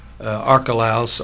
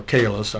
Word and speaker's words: Archelaus: Archelaus, or Calus, I've heard it pronounced both ways, was reigning, but instead to go back to Nazareth.